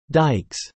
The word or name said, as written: dikes